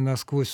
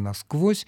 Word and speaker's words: насквозь: По поводу употребления наречия «насквозь» с ударением на первом слоге, эта вещь вполне объяснимая. Ну, конечно, словари нормативные рекомендуют ударение на корне, «насквозь», но существует много наречий, в которых ударение оказывается на приставке.